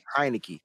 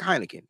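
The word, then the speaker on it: Heinicke: to Tyler Heineke. How you say his name? – Heineke. – Right? Like Heineken.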